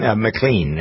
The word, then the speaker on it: McLean: Lee Greenwell, he's got two, so it's sort of a tie with Jeffrey Goad, G-O-A-D is the last name. He says American Pie, John McLean, McLean actually is how he pronounced his name, Canadian, even though he sang American Pie, bye, you know that song.